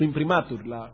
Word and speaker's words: imprimatur: Su imprimatur, el acento en la A.